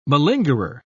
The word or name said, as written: malingerer